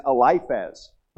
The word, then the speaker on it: Eliphaz: It's Eliphaz, but I call him Eliphaz. All right. So if you go, who is Eliphaz? It's Eliphaz. I just mispronounced it.